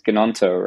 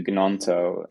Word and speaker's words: Gnonto: I might have to make Martial into Gnonto and Darwin into Kane. And obviously, I'm fairly certain, by the way, you don't pronounce the G.